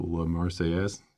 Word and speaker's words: La Marseillaise: I believe it's La Marseillaise. – That's close enough.